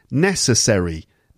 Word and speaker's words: necessary: Anyway, necessary. Necessary. Necessary. Okay, necessary. First syllable.